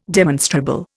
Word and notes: demonstrable: Indian

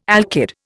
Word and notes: alkyd: Indian

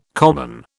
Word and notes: common: British English

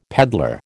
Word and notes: pedlar: American English

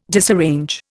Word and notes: disarrange: Indian

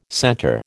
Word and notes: center: American English

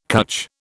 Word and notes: cutch: British English

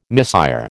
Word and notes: misère: American English

